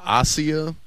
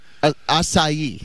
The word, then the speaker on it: acai: I was calling it Asia. – Acai.